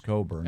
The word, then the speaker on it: Cockburn: It is spelled Cockburn, but it's pronounced Cockburn.